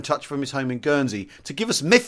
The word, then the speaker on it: Guernsey: Mefew means attention in the language of Guernsey, which is Guernsey, pronounced with a soft G, like the word gin. So Guernsey. It's three syllables. Guernsey.